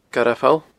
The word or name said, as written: Garbh Pholl